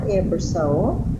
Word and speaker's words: Ebersole: I'm Wanda Coward Ebersol. – And how do you spell that for the page? – C-O-W-A-R-T and E-B-E-R-S-O-L-E.